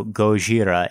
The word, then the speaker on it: Gojira: The second one can be pronounced either G, Goji, or Z, D-Z-I. So Goji, and then in Japan, there is no L. There's no equivalent for the L sound. So Ra became La. So Gojira and Godzilla are essentially the same thing.